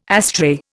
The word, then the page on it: astray: Indian